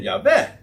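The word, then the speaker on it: Jahweh: En dan lees je later in de geschiedenis, en Jaweh God maakte voor de mens en voor zijn vrouw, uh, nou, ik moet de klemtoon anders leggen. En Jaweh, maar God maakte voor de mens en voor zijn vrouw klederen van vellen, namelijk van dierenhuiden, en bekleedde hen daarmee.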